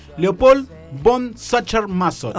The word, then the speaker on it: Leopold von Sacher-Masoch: Leopold von Sacher-Masoch. – Oh, bien, bien. – Siempre cuando es alemán, hay que decirlo como enojado. – Claro. – Leopold von Sacher-Masoch.